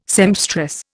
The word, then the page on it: sempstress: Indian